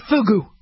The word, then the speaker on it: fugu: Is it fuju? Let's just call it fugu. Make sure the U is long sounding, not the short U, okay?